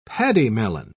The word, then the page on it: pademelon: British English